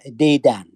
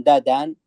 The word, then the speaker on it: دادان: ولكن تنطق أحيانا دادان أو بعض الناس ينطقونها ديدان، لكن دادان هي في الواقع يعني أفضل، أو ددن كما أسماها أهلها وقاطنوها.